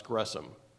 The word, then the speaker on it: Gresham: The H is silent in Gresham. I always thought it was Gresham, but it's apparently pronounced Gresham.